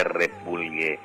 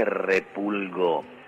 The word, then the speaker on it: repulgo: Pero que no se dice repulgue, se dice repulgo. Repulgo. Aprendamos bien nuestras palabras. Repulgo.